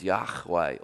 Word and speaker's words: Yahweh: Anyway, so this name, Lord, is Y-H-W-H in English. And the closest we can come to figuring out how it's pronounced is Yahweh. Or if you don't want to, you can go Yahweh.